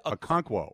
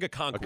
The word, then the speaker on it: Okonkwo: How do you pronounce it? – Okonkwo. Oh, now Okonkwo. I'm getting it wrong. Chig Okonkwo.